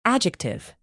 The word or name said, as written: adjective